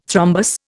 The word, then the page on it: thrombus: Indian